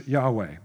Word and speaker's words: Yahweh: Capital L-O-R-D. Who's the Lord? It's the name in Hebrew of the Tetragrammaton. Okay? We would pronounce it in English, Yahweh.